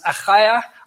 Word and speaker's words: Achaia: or it's pronounced Achaia. I can say that because I'm Afrikaans, who believe.